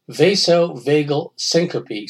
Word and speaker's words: vasovagal syncope: He suffered from," okay, it's pronounced vasovagal syncope.